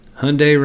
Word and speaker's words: Hyundai: Hyundai rhymes with Sunday.